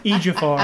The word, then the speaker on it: Ejiofor: I like how you say his name different almost every time. Ejiofor, I believe it is. – Ejiofor. Etchual. – Chiwetel Ejiofor. I think that's what it is.